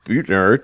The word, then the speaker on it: Buettner: I want to welcome Robert Buettner to the podcast.